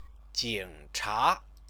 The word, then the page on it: jingcha: Chinese